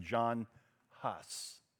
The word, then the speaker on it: Jan Hus: One such man is Jan Hus. Jan Hus. Anybody who knows the history of the Protestant Reformation knows the name John Hus. That's how we would pronounce it.